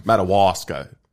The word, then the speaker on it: Madawaska: How do you say it? – Madawaska.